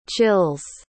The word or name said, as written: chills